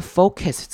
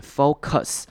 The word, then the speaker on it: focus: 好，看到 focus 这个字，还记得上礼拜也有提过，这边的 us 的声音我们会念成短母音 i，所以我们不会念成 focus。